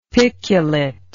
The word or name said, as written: piculet